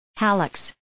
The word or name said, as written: hallux